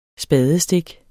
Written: spadestik

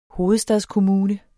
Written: hovedstadskommune

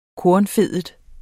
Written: kornfedet

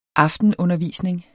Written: aftenundervisning

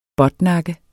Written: båtnakke

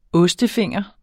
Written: ostefinger

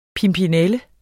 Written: pimpinelle

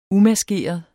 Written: umaskeret

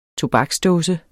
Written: tobaksdåse

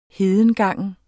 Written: hedengangen

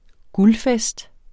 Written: guldfest

